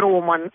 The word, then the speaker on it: роман: Вот придумали такой роман, обязательно ударение на «о».